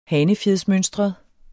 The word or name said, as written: hanefjedsmønstret